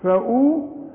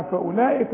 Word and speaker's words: فأولئك: فأولئك اهي، الغي الحرف ده في النطق، يكتب ولا ينطق، تقول فأولئك، ما اقولش فأو، فأو، فأولئك، قل لي فأولئك تاني،